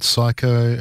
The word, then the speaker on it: Cycco: We also had Psycho, which is spelled C-Y-C-C-O, with her track Panic.